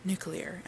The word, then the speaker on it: nuclear: According to, I don't know who the hell says nuclear is correct, but it's nuclear, people. Nuclear. There's not an extra U in there.